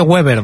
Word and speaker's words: Weber: Está Manfred Weber, que se dice Weber, no se dice Weber, ¿vale? – Sí, se dice Manfred Weber.